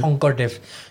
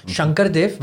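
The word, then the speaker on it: Sankardev: Honkar Dev. Shankar Dev, but the way we pronounce Honkar Dev.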